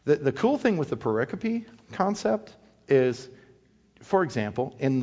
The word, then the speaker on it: pericope: It's spelled like pericope, P-E-R-I-C-O-P-E.